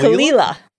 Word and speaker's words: Caol Ila: First of all, it's spelled, actually, I'll just show you the bottle. It's spelled C-A-O-L-I-L-A. And that's... – that's pronounced Kalila? – Kalila.